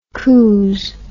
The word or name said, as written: couze